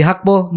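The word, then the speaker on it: Gakpo: yang kedua ada transfernya Cody Hakpo. ini baca apa cara bacanya orang Belanda ya. Yo. Iya. G baca H. Hmm, kayak Ten Hag. Ten Hag. Bau ya. Cody Hakpo merapat ke Liverpool Eh,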